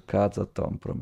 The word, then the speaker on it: Kazatomprom: Kazatomprom. – Sì, io non lo so leggere. Kazatomprom, esatto.